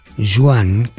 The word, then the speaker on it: Joan: Joan, que así es como se dice en catalán, aunque se escribe con una O,